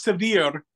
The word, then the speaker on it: Sevier: Did you say my name right? I can't hear because it's going in and out. – Yes, I did. So said your name, Mikey Sevier. – It's Sevier.